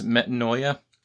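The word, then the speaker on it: metanoia (μετάνοια): The Greek word for repentance is metanoia, pronounced in Erasmian as metanoia.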